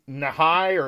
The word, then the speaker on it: Nighy: Do we ever decide how we pronounce that? – I always say Bill Nighy, even though it's close. Nighy, it's okay. Like Bill Nighy the Science Guy, but I'm pretty sure that it could be Nahai or Nighy, I don't know.